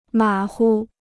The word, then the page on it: 马虎: mǎ hu